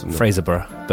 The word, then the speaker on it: Fraserburgh: But yeah, apparently it's pronounced the same way as Edinburgh. – It makes sense. – Fraserburgh.